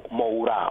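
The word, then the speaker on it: Moura: όχι για την ερμηνεία του Μοουρά, όπως έμαθα ότι τελικά προφέρεται το όνομά του, και όχι Μούρα,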